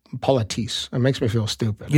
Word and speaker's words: politesse: Politis. – Politis. – Why does yours have such a robot voice? – That's YouTube. – Oh. – Yeah. – It rhymes with elitist.